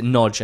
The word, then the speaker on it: Nagy: And it's important to pronounce it Nodge and not Nagy.